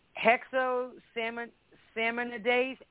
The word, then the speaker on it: Hexosaminidase: Hexosaminidase A.